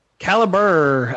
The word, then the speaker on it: Calibre: Will is sending me notes now that it is pronounced Calibre. – Calibre, according to the Oxford English Dictionary, that is pronounced Calibre.